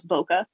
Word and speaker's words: bokeh: Some people pronounce it a little bit differently, but I always had heard it pronounced as bokeh.